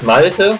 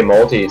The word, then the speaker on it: Malte: In Germany, it's Malte, but usually the Americans say Malte, so that's all right.